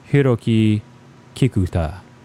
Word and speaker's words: Hiroki Kakuta: This is music by Hiroki Kakuta. Okay, if I was going to try to pronounce that Japanese style, be like Hiroki Kakuta.